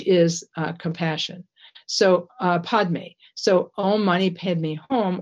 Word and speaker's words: Om Mani Padme Hum: He said, teaching the mantra, Om Mani Padme Hum, which is the way it's pronounced in Sanskrit, or Om Mani Padme Hum, which is the way that it's pronounced in Tibetan.